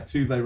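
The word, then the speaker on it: Tove: Yeah, Tuve rhymes with Duvet, let's never so, John, I believe you have a word from our sponsor.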